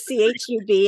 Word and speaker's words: CHUB: So were you, and I apologize, I didn't know it was CHUB. I should know better than that. So, and I pronounced it Chubb Radio.